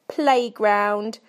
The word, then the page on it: playground: British English